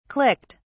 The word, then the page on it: clicked: British English